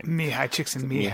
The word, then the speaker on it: Mihai Csikszentmihalyi: Mihai Csikszentmihalyi.